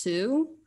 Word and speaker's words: Sault: I just discovered a new band spelled like Salt, S-A-U-L-T, but I believe it's pronounced Sue.